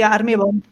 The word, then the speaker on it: Army Bomb: Sí, Army Bomb.